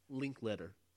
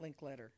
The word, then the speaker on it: Linklater: Linklater. – No, it's not Linklater. – It is Linklater. – Well, it's not spelled Linklater. – I know, but he pronounces it Linklater.